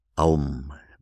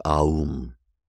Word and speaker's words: Om: Om, but it's pronounced Om. It's not pronounced Aum, pronounced Om.